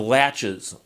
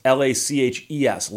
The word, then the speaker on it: laches: laches. Laches, and it's spelled differently than it sounds. It's L A C H E S, laches.